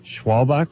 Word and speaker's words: Schwalbach: Kevin Smith is here, and Jennifer, and tell me if I mispronounced this, Schwalbach. – Perfect.